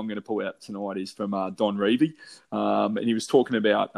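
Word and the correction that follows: Revie: Did I say it wrong? – I think you'll find it's Revy.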